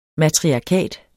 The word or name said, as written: MATRIARKAT